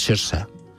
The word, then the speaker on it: Saoirse: Saoirse Ronan, que como es gaélico es Saoirse. Es como se pronuncia.